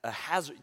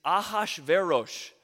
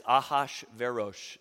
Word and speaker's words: Ahasuerus: Like, he starts off with reading it, and he's like, and King Ahasuerus, do you know people call it Ahasuerus? Who is this King Ahasuerus? It's Ahasverosh. So that's how you pronounce it. And I must have said it like 50 times. I was just trying to get it right, just for this moment, to tell you it's Ahasverosh.